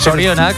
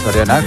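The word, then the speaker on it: zorionak: Sorionak. – Sorionak, claro.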